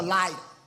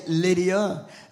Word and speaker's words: Lydda: is that he went to Lydda. Some say Lydia, which is spelled L-Y-D-I, but Lydda is L-Y-D-D-A. That's the way they pronounce it, and some pronounce it different ways.